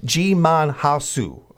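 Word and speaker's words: Djimon Hounsou: Ji-Mon Hasu.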